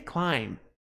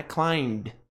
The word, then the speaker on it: climb: Pessoal, importante, o verbo escalar não é climbed. O B não é pronunciado.